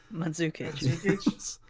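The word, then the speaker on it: Mandžukić: I couldn't say Mandusic. Couldn't say his name last week. – Oh, Mandzukic.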